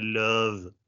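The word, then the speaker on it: œuf: Ja, det har jag faktiskt kollat upp med, med expertis och det heter löv. Löv. Ja, man blandar ihop, alltså sista, sista uttalet där är en skön blandning av V och F.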